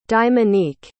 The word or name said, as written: diamonique